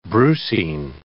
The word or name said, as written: brucine